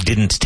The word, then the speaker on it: didn't: We didn't take a break. Excuse me. We didn't take a break. We didn't take a break. Pronounce the T in didn't. And both D's.